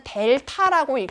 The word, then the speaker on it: 델타: 근데 이 앞에 있는 이 삼각형은 무엇이냐? 얘는 델타라고 읽습니다. 델타.